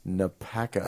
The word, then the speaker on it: NAPACO: NAPACO. – Acronymized. – Sounds harmless enough. – Rhymes with tobacco.